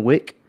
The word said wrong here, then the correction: Wake: 2? – Wake. – There we go.